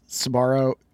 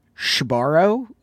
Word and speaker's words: Sbarro: I actually heard you pronounce Sbarro Shbarro, which is a huge red flag for mall rats like me.